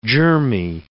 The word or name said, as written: germy